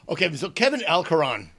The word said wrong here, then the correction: Alarcon: How do you pronounce that name? – Alarcon. – Alarcon. Ah, Kelvin Alarcon.